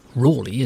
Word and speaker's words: Raleigh: Another of the accused, none other than Sir Walter Raleigh, or Rawley as he would have pronounced it, knocked a big hole in the prosecution case when he revealed that Wade had been torturing witnesses against King James's explicit order.